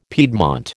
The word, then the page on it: piedmont: American English